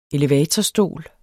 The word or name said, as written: elevatorstol